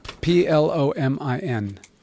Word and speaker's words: Plomin: P L O M I N.